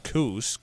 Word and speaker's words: Kuss: City Administrator Lisa Kuss, which is pronounced Coos, K-O-O-S, for those of you in Merrill, says she has again contacted the Geological Survey about latest booms.